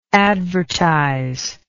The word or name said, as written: advertisable